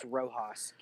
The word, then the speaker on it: Rojas: And Coach of the Year, Luis Rojas, New York Mets. – Hey, it's pronounced Rojas. Get out of here. – New York Mets. – Okay, first rule of Spanish. A J is pronounced with the sound.